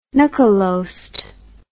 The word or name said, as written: nokkelost